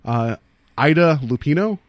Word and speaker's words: Lupino: So, cast, the star who, Barbara Jean Trenton, Ida Lupino, is that how you pronounce her last name? – Yeah.